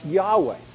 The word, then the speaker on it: Yahweh: But the Jews didn't pronounce the name, and that's not how it was pronounced in Hebrew. It was pronounced Yahweh.